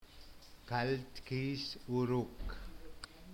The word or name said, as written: kaltkisuruk